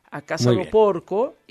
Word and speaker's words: A Casa do Porco: A Casa do Porco, y luego Quintonil en el noveno. – Me encantó, oye, me encantó tu pronunciación, La Casa do Porco, ahí, extraordinario.